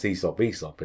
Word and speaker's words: Aesop: But all the other pronunciations we have in modern English now seem to be the E pronunciation, as in the demons, where you have, well, I don't know, for example, and this is not a great example, but Aesop. We pronounce Aesop in English, and that's the AE thing.